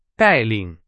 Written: 带领

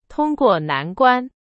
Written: 关

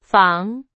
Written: 防

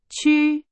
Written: 区

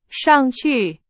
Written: 上去

